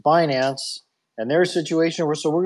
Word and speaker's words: Binance: I think it's pronounced Binance. – It's Binance.